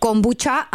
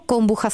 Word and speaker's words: kombucha: Chceš kombuchu, máte taky na Slovensku kombuchu a vyslovuješ to kombuča, anebo kombucha s ch.